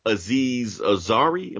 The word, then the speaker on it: Aziz Ansari: The other incident we're going to bring up is with actor slash comedian Aziz Ansari. Am I saying that right? – Yeah.